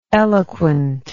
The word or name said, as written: eloquentness